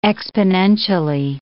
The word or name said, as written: exponentially